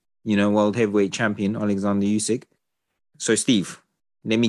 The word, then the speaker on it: Oleksandr: Yeah, Oleksandr. It's not even Alexander.